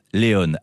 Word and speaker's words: Léon: Léon, c'est une fille et ça va vous épater, vous allez voir, elle est suédoise et surtout, ça se prononce Léon.